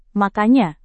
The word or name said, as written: makanya